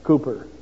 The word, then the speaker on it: Cowper: Cooper, yeah. Can you remember one? All right. If you look in our hymn book, under C-O-W-P-E-R, pronounced Cooper, William Cooper, you'll find wonderful hymns written by this man.